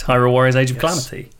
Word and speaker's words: Hyrule Warriors Age of Calamity: Did I get it right? I feel like I've said that wrong every time. – No, it's correct. Hyrule Warriors Age of Calamity.